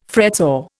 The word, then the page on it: fretsaw: Indian